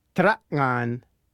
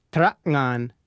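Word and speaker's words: tera'ngan: Traknan. The sound at the beginning of the second syllable, ngan, is the same as the sound that comes at the end of the word thing or hang.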